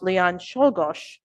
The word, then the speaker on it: Czolgosz: In 1901, a professed anarchist, and I believe the name is pronounced Leon Czolgosz, shot President McKinley.